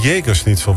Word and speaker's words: Jägerschnitzel: Het is dan misschien de Jägerschnitzel, want zo spreek je dat.